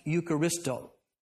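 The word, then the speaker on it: Eucharisto (ευχαριστώ): Because in the Greek word for giving of thanks is pronounced Eucharisto.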